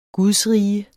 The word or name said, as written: gudsrige